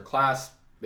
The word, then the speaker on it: Claise: It's literally just my name, Hunter Class. It's spelled real weird, Hunter, and then C-L-A-I-S-E.